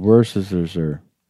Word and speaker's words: Worcestershire: It's called Worcestershire.